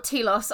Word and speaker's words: Telos: On Telos, I know it's pronounced Telos, but I like saying Telos.